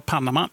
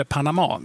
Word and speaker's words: Panama: Då i VM 2018 så var Panama, eller Panama, vi kommer att använda båda uttalen under natten i det som vi kommer att kalla Panama-kanalen.